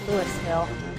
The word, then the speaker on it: Louisville: Louisville. That's not how you say it.